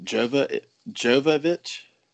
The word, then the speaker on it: Jovovich: I'm gonna butcher her last name. Is it Jovovich? – Yes.